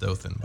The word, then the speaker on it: Dothan: It's pronounced Dothan. – Oh, Dothan, okay.